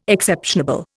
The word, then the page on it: exceptionable: Indian